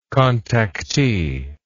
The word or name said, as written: contactee